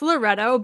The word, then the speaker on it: Loreto: It's Loreto, but like, be fancy.